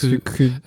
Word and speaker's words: jökull: Aber es ist Jükyt. – Also das Doppel-L soll wohl, was am Ende ausgesprochen wird, wenn kein Vulkan, kein Vulkan auf dem Doppel-L folgt, ne? Ist das wohl wie ein T.